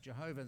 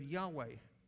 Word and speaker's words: Yahweh: You know, at the time when Jesus was on earth, the word for God, YHWH, which some people in the 19th century thought was best pronounced Jehovah, they now know that was a mistake. And then we came up with the word Yahweh.